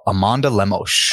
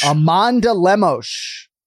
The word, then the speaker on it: Amanda Lemos: You're talking about Amanda Lemos. – Amanda Lemos. Let's hear it. – Mm-hmm. Amanda Lemos.